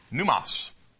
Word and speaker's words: pneumos: Pneumos. P is silent.